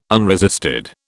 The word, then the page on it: unresisted: British English